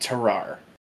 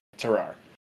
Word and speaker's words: Tarrare: This guy is known as, and this is a hard name to pronounce, I've seen it pronounced multiple different ways, we're going to go with Terrare. T-A-R-R-A-R-E. Terrare. – And you know what, if you're wrong, I mean, it's French. Who cares? – Yeah. Yeah. So Terrare was born in France near Lyon in the early 1770s.